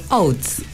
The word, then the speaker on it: Oates: Oates.